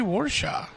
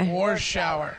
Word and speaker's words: Warshaw: Like war and shower. – War shower.